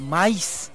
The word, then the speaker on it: maíz: ¡Viva Tehuacán, la tierra del maíz! Maíz, acento en la I.